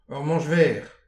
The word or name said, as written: ur manch verr